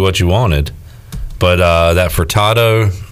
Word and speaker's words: Furtado: There's no R. – Furtado.